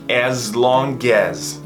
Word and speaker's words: as long as: As long as. Esse AS tem som de Z.